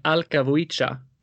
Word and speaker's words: Alka Vuica: And with lyrics written by Alka Vuica.